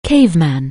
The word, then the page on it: caveman: anglais